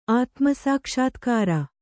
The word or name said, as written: ಆತ್ಮಸಾಕ್ಷಾತ್ಕಾರ